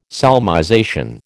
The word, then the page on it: solmization: American English